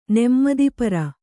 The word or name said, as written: ನೆಮ್ಮದಿಪರ